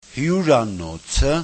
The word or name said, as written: hyuranots@